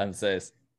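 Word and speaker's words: ANSES: Ansés. – Sí, es Ansés. Sí, igual está mal porque no tiene acento en la E, así que no está mal decir Ansés.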